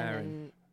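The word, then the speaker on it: Erin: Like, I'm from Boston, Aaron. And we say it like. My name, E-R-I-N-E-H-R-I-N, and then the other Aaron. – One is like Air.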